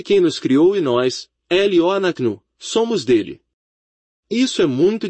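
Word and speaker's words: Loanaknu (לא אנחנו): Qual é a diferença sonora entre Loanaknu e Loanaknu? Diga-me qual é qual. Você não pode. É pronunciado da mesma maneira. É Loanaknu.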